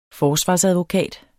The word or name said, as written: forsvarsadvokat